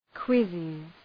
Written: quizzes

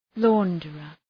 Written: launderer

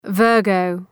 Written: Virgo

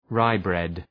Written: rye bread

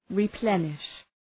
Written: replenish

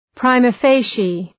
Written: prima facie

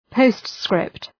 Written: postscript

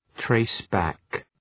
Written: trace back

